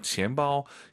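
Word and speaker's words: 지엠빠오 (钱包): 네, 지엠빠오. 약간 여러분들께서 쌍비읍이 너무 강해서 지엠빠오 이렇게 하는 것보다는 약간 선생님 쌍비읍과 비읍의 중간은 지엠빠오 어때요? 지엠빠오 괜찮습니까? – 괜찮습니다. – 너무 힘드시면 지갑을 그냥 두고 다니세요. 지엠빠오 다 좋긴 합니다.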